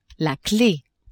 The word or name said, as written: clef